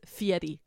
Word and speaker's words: Fieri: It's Fieri.